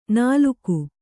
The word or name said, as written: ನಾಲುಕು